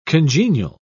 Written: congenial

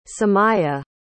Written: samiya

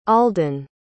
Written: alden

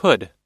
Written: pud

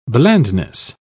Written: blandness

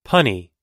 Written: punny

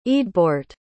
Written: eadbeorht

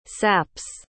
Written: saps

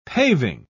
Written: paving

